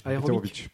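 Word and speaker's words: Eterovic: J'ai eu la chance de lire un livre de Ramón Díaz Heterovic, qui, je le prononce bien, ça va ? – Heterovic, pardon.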